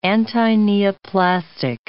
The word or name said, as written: antineoplastic